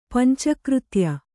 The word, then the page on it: ಪಂಚಕೃತ್ಯ: panca křtya